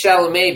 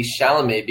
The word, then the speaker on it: Chalamet: You know, it's important that it's Chalamet because I wonder if this author would fuck Timothy Chalamet, or they chose Timothy Chalamet because his name rhymes with gay.